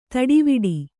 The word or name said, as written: ತಟವಟ